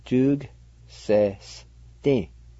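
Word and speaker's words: tsugasesdi: The second part is pronounced like this, and the A with G is silent in this word, like this, jug sesti.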